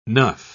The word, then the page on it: nuff: British English